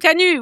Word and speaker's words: Canuts: Which I thought, pardon, Canuts, oui. They don't say the T, that's right. – We don't say the T, exactly. This is France. We don't pronounce the last consonant.